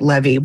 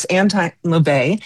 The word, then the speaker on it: Anton LaVey: I pronounced it Levy, which was completely wrong. It's Anton LaVey.